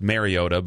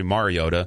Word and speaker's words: Mariota: Well, when you think nationally, too, the former starting quarterback turned caveman has always been pronounced Mariota by exactly. – a lot of national pundits when it's actually Mariota.